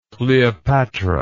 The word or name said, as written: kleopatra